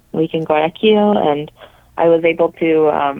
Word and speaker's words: Guayaquil: Guayaquil. I hope I said that right. – Yes, Guayaquil.